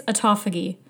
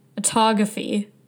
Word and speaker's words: autophagy: I think it's autophagy. I kept saying autography, like photography. I think it's autophagy.